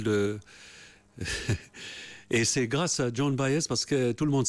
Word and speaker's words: Joan Baez: Vous ne le prononcez pas pareil, Baez. – On dit Joan Baez, oui.